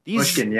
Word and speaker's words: Mushkin: Mushkin, yeah.